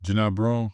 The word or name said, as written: Johni Broome